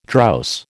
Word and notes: drowse: American English

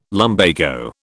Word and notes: lumbago: British English

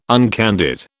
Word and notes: uncandid: British English

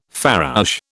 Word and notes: farouche: British English

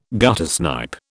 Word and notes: guttersnipe: British English

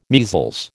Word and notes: measles: American English

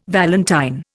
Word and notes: valentine: Indian

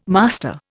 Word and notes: master: Indian

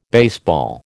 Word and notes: baseball: American English

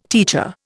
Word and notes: teacher: Indian

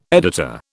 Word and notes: editor: British English

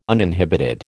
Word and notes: uninhibited: American English

